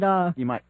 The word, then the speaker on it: Imai: I believe the starter's name was Imai, right? Is that how you say his name? – Yeah. – Yeah. – Is that how you say his name? Was it... – Imai. I-M-A-I.